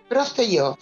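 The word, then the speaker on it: Prostějov: Prostejov, sí, porque la J en estas lenguas eslavas suele ser una iota.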